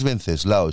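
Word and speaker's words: Wenceslao: Sí, a mí mira, yo me llamo Lao, pero es mi nombre es Venceslao y se escribe con W.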